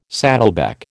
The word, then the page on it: saddleback: American English